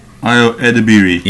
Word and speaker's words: Ayo Edebiri: Ayo Edebiri.